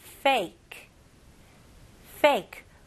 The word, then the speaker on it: fake: 영어로는 P 발음하고 F 발음을 확실하게 구별하셔야 됩니다. 아니면 원어민들이 이해 못할 수도 있어요. 오케이? 이거는 F, F, 입술이 붙으면 안 되고요. Fake, fake, 윗니가 밑에 입술로 닿으면서 fake, very good.